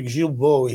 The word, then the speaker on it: Guilbeault: I mean, the trouble with Mr. Guilbeault, he's one of these very, very sincere people. Oh, you said it right.